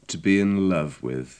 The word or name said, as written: to be in love with